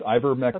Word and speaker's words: ivermectin: It's ivermectin.